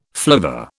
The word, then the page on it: flivver: British English